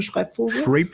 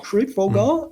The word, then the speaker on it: Schreibvogel: Also heißt die Hauptfigur eigentlich Joe Schreibvogel. Oder Schreibvogel, die können das alle nicht aussprechen, das ist deutsche Schreibvogel. – Schreibvogel.